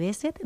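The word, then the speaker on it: Behçet: Os voy a presentar a Nuria Zurdo, que, bueno, es enferma de Besset. No sé si lo he dicho bien, Nuria. – Sí, sí.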